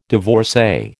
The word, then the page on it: divorcee: American English